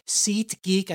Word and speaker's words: SeatGeek: SeatGeek, I sometimes don't enunciate my T's, my wife tells me. And so listeners who pointed that out, sorry about that. But let me tell you, SeatGeek, they're a great, low-cost, super convenient way to buy tickets for live events.